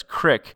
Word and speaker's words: crick: The word Crick, which Shadow notes is pronounced by locals as Crick, is a fairly American word.